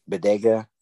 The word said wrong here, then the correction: Boyega: I think that's or Boyega. I might be saying his name wrong, but he was in some of the Boyega. Star Wars. Yeah, John Boyega.